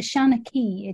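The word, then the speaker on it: seanchaí: Shaughnessy, a traditional storyteller. Can you tell us a bit about that? – I can. It's I'm not correcting your pronunciation. I just have an alternative version. It's a Shaughnessy.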